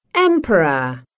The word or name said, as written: emperor